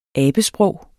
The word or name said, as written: abesprog